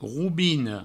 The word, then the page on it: robina: roubino; Provençal